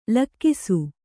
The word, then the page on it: ಲಕ್ಕಿಸು: lakkisu